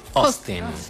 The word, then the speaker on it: Austin: Austin.